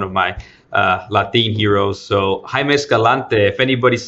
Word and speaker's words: Jaime Escalante: And, you know, your quote is sort of strikes the same vein here, and it's by Jaime Escalante. Did I pronounce that right? – Jaime Escalante. – Yeah. Jaime Escalante.